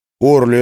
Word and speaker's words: Орли: «Орли, это по-французски?» — спросил он. – «По-французски «орли», — ударение на последний слог.